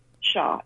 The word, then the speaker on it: Shya: Oh, it should just be Doc, D-O-C, Shah, S-H-Y-A. It's not Shia, it's just Shah. The Y is silent. It is a foreign name.